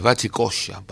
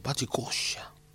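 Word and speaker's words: Bate Coxa: El título es Bachicoya. Bachicoya. Batecoxa se escribe.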